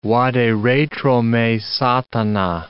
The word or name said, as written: vade retro me, Satana